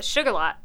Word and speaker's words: Shuqualak: There's a town called, they call it Sugarlot, but it's spelled Shuqualak.